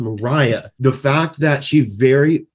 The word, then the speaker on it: Maria: starts with Mariah, yes. who is, you know, I'm usually rubbish with remembering names, but I remember this because she insists that it's pronounced Mariah, not right. Maria.